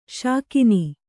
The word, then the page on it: ಶಾಕುನ: śakuna